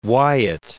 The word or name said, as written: Wyat